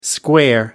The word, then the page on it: square up: American English